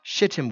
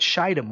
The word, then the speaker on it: shittim: It's pronounced shittim wood. Some people in the ministry call it shittim wood because of the association.